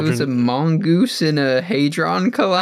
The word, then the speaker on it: Hadron Collider: We haven't even gotten to the the Hadron Collider, or am I saying that right? – The Hadron Collider.